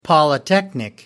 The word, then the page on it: polytechnic: American English